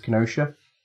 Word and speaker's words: Kenosha: You know, I think the first time I pronounced it correctly when we were talking, and it is Kenosha, I just butchered it every single time since then.